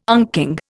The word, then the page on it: unking: Indian